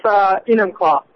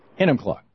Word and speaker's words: Enumclaw: And listening on KBCS in Enumclaw, I'm sure I'm saying that wrong, Washington. Hey, how do you say your tone? – It's Enumclaw. – Enumclaw. That makes sense.